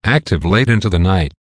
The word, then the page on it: active late into the night: American English